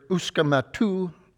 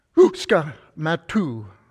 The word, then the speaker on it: Uskumatu: And she introduced us to the Estonian word, Uskumatu, which she said should be pronounced like this. The intake of a deep breath, Uska, followed by a sharp exhale of Matu. Let's try it together. Uska, Matu.